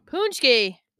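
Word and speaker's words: pączki: The Pachki. – Punchki. – Punchki? – Yeah. – There's no N. – I know, but that's how it's pronounced.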